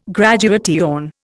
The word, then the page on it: graduation: Indian